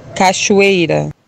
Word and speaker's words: Cachoeira: Priscila Cachoeira. Cachoeira. – Cachoeira. Like Cachoeira. A fucking cashew, right? Cachoeira. Maybe not. – Cachoeira. – All right. Those are your pronunciations.